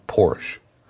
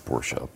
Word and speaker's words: Porsche: It, Tesla actually tied for the best road test score from Consumer Reports with Porsche. – It's actually pronounced Porsche, but still.